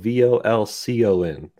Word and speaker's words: Volcon: Volcon spelled V O L C O N. So am I even saying that right? yeah, forgetting. – okay, Volcon.